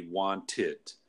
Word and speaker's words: wanted: So the past tense of want would be wanted. – Because the ending sound is a T. – Right, right. – So we actually pronounce the E here. – Yeah, and you're adding a syllable.